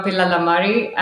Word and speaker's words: Pillalamarri: I am Pratibha Pillalamarri.